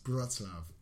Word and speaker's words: Wrocław: It's spelt Wrocław. No. But it's pronounced Wrocław.